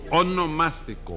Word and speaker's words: onomástico: Sí, eso mismo, su monoelástico. – No, no, no, su onomástico.